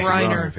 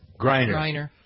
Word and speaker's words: Griner: I always pronounce Griner. – It wrong. – Griner.